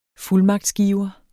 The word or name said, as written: fuldmagtsgiver